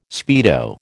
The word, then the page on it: speedo: American English